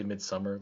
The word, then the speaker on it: Midsommar: Well, it, you can pronounce it mid midsummer, but it's but but it's actually midsummer, like like the English English pronunciation.